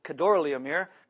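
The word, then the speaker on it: Chedorlaomer: Aramphel, king of Shinar, Arioch, king of Elasar, and I had to look up how to say this, it's Kedorlaomer. Kedorlaomer, king of Elam, and Tidal, king of Goyim.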